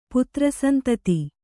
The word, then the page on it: ಪುತ್ರಸಂತತಿ: Kannada